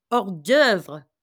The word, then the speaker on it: hors d'oeuvre: And she's, so she says to me, the pronunciation is hors d'oeuvre.